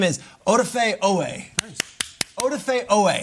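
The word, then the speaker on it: Odafe Oweh: I have mispronounced his name over 100 times on this very show. – Whose name? Odafe Owa? – No. Odafe Owe.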